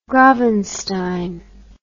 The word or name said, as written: gravenstein